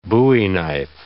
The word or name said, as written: bowie knife